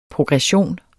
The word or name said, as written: progression